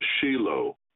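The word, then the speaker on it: Shiloh (שילה): There's a Hebrew word which I mispronounced, but it's pronounced Shiloh, but it's written not with a vav at the end, but with a hey.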